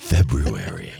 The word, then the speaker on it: February: Rush Limbaugh used to say with the February, because notice the correct pronunciation. He used to remind the listeners of that. – February. – Right.